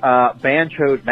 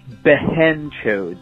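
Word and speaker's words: behenchod: Apparently, banchode, matterchode is actually behenchode. B-E-H-E-N-chode, and modderchode, M-A-D-A-R.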